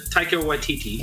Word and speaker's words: Taika Waititi: Taika Waititi. – Taika Waititi, yeah.